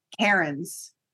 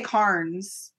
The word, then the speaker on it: Cairns: And no, I had to Google how to pronounce the name of the place in Australia they're in. – Where? Okay, what is it? – Cairns. – Cairns. Yeah. – Yeah. Cairns. I wanted to say Carnes, but it's Karin.